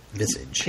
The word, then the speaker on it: visage: Visage. – Visage. Visage has to do with things. – Visage. Visage. – See? – I just clicked on the word in Wikipedia to pronounce it for me.